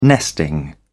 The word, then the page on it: nesting: British English